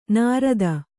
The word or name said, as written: ನೈಋತ